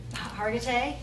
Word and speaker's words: Hargitay: that Mariska Hagerty, is that how you pronounce it? She's from one of Hargitay.